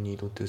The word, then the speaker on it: need to: で、 will need to select V の U が S の、 a material が O で、ですが、資材を選んでいただく必要があります。で、発音ですけど、 bad の T が抜けますね。 by you。 bad you じゃなくて、 by you。あと、 need の D が抜けますね。 need to。